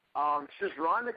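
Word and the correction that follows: Schisandra: kind of threw me off. – Shizandra? – Yeah, that's it. Pronunciation.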